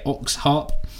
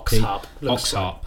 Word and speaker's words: Okzharp: But this is one from a brand new artist called, I'm going to say Oxharp, which is spelled O-K-Z-H-A-R-P. – Oxharp. – Oxharp.